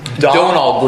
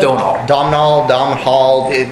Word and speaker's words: Domhnall: Donal Gleason, I think is how you say it. – Donal Gleason? – Domhnall.